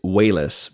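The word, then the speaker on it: Walas: I want to say it's pronounced Wallace. Do you know differently? – I do know differently. It's Wayless, because I Wayless. Just listening to the commentary, he's like, I'm Chris Wayless. And I was like, okay. That's how you say it.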